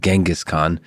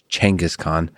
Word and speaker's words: Genghis Khan: Most of my life said Genghis Khan, but the right pronunciation is actually Genghis Khan.